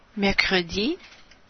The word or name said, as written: mercredi